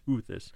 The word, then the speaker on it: Teuthis: No, it's spelled Teuthis. It looks like, but I think it's pronounced toothis.